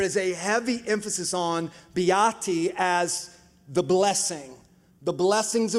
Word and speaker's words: beati: The Beatitudes are from the Latin word beati. If you say it like with a little, you know, Italian flair, beati, forget about it, right?